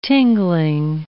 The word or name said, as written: tingling